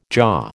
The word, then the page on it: jaw: American English